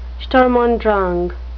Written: Sturm und Drang

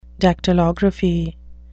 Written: dactylography

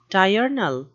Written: diurnal

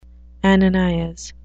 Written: ananias

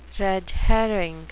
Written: red herring

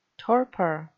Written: torpor

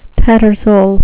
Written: tattersall

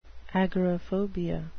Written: agoraphobia

